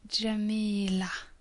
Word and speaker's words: Jamila: It's pronounced as a long A sound. So the word written is Jamila with A at the end.